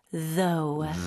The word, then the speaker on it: though: TH 발음 신경 써주셔야 돼요. 혀가 이 사이에 진동하는 느낌으로 though.